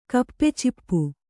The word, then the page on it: ಗಪ್‍ಚಿಪ್: gap cip